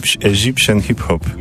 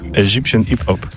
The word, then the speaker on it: Egyptian Hip Hop: Zespół nazywa się Egyptian Hip Hop, wymawiając to bardziej po egipsku. Egyptian Hip Hop. Egyptian Hip Hop.